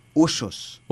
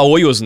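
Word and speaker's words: Hoyos: Oxos. Todo mundo fala oios, né? – Ou rojos. – Ou rojos. – Tem, o H não tem som de R. – Tá. – É mudo. E o Y tem som de J.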